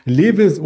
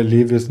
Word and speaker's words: Levi's: Und da gibt es in dieser Woche beispielsweise noch Levis oder Tilray. Da geht es um Cannabis und bei Levis natürlich oder Levi's, wie es ausgesprochen wird, geht es um ja, Bekleidungsmittel und eine Biofrontera.